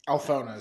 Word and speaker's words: Alphona: Is that Alphona? Alphona? – Alphona is how it's pronounced.